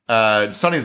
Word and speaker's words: Suni: Sonny's name, by the way, is spelled S-U-N-I because her mom's of Indian descent. But like I've seen it pronounced Sonny a lot, so I'm going with Sonny.